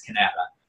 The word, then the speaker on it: Kanata: K-A-N-A-T-A. Everyone thinks I'm an idiot trying to pronounce Canada. Canada, but it's Canada.